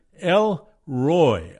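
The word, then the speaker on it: El Roi: the word El Roy, R-O-I. It's pronounced Roy, almost as if the I was a Y. But it's Hebrew, and L-E-L is an abbreviation for the word Elohim, which in Hebrew is the Lord God Almighty.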